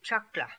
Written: Chakla

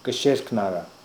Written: Ksherknara

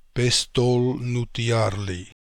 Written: Pestolnootiarly

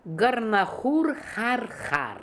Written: Gornahoor Harharkh

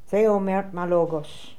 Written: Theomertmalogos

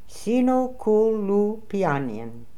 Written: Sinokooloopianian

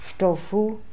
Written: Ftofoo

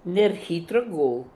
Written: Nerhitrogool

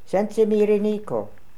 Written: Sensimiriniko

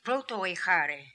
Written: Protoehary

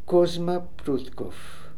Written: Proutkoff Kusma

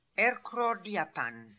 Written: Erkrordiapan

